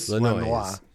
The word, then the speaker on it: Lanois: Daniel Lanois. – Lanois. – Lanois.